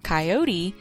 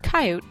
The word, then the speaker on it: coyote: Although the preferred pronunciation is the Mexican-Spanish version, coyote, the shortened coyote is commonly used.